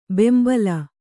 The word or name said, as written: ಬೆಂಬಲ